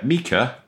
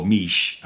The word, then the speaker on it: Miche: So it's Mika, as my Italian partner tells me it's pronounced, or Miche, as most of us ignorant non-Italian English speakers say.